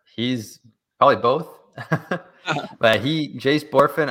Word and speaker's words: Bohrofen: It's pronounced Borfin. However, it is spelt B-O-H-R-O-F-E-N. So it looks like three syllables and it's spelt a little weird, but it's Jace Borfin. Again, that's B-O-H-R-O-F-E-N.